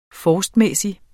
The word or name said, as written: forstmæssig